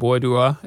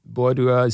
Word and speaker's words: Boadua: So the book, again, is The Boy from Bordua, and Bordua is spelled B-O-A-D-U-A. – Bordua. – Bordua, right. Yes.